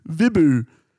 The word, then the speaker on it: Vibhu: It's not even that hard of a name, Vibhu. – It's like, the silent H that throws people. It's spelled V-I-B-H-U. – Even if you were to go with the H and go Vibhu. It's close enough, Vibhu.